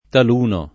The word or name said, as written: taluno